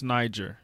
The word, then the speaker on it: Niger: It's pronounced Niger.